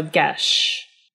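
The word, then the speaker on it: geis: According to their pronunciation guide, G as in guy, short E as in bed, so ge, and sh as in shy, so gesh.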